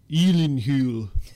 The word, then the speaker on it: Gyllenhaal: Okay, so the actor's name, and I have trouble pronouncing it, it's Jake Gyllenhaal.